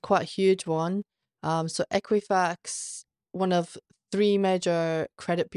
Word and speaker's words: Equifax: Equifax. – Equifax, okay.